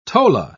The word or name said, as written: tola